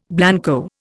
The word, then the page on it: blanco: Indian